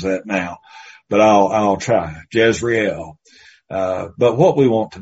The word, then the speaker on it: Jezreel: How is it properly pronounced? Jezreel? Okay, Jezreel. Well, all of my 150 years of preaching, I've always called it the Valley of Jezreel.